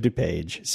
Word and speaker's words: DuPage: College of DuPage.